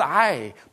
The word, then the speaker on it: Ai: miraculous way into a tiny little town called Ai. By the way, do not try to pronounce it the way I do. You'll get tonsillitis. It's spelled A-I.